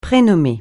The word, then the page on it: prénommer: francais